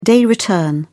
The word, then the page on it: day return: British English